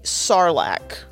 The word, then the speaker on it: Sarlacc: On the side of this, I'm going to have the Surly Sarlacc.